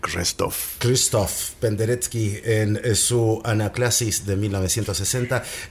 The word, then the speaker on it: Krzysztof Penderecki: Escuchamos aquí en Raras Músicas Anaclasis para cuerdas y percusión, composición del año 1960 de ¿cómo se dice? – Krzysztof Penderecki. – Penderecki, mira, bien, Penderecki.